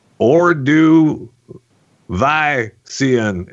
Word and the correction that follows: Ordovician: Now, I mean, I'm not sure if uh, that's exactly how you pronounce it. So I Ordovician. division. – Or division. Wait, what was it? – Ordovician.